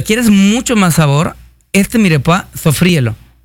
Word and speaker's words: mirepoix: Se escribe mirepoix con X, uh-huh. Pero es el mirepoix.